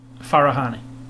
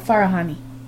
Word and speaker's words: Farahani: Goldshifta Farahani. – Farahani. – Oh, Farahani.